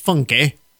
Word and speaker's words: Funke: It is named in honor of celebrity chef Evan Funky, spelled F-U-N-K-E, but pronounced Funky.